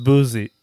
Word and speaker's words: Boosie: It's pronounced Boosie. – It's pronounced Boosie. It's not a Z. It's an S, man.